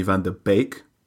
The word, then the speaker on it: van de Beek: Well, for starters, it's pronounced Donny van de Beek.